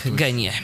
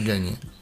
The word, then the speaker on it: Genie: Pisze się to Genie. – Tak, Genie. Niestety, Genie.